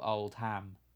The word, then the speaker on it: Oldham: Oldham, which doesn't really work because it's spelled Old Ham, but you say Oldham.